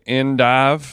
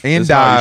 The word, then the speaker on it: endive: No, I'm saying you're so white that you should say endive. – Endive. – You should do it. So you're taking the first syllable French and then the last syllable English. You got to go American all the way.